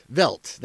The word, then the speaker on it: Veidt: Veidt.